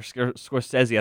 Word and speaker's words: Scorsese: So Martin Scorsese, or Scorsese, I think is the correct way to say it, he said that Marvel doesn't even make movies anymore, which I actually tend to agree with.